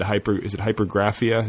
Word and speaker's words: hypergraphia: I know that you said you had hypergraphia? Is that how you pronounce it? – Yeah, I had some kind of like weird hypomanic fit.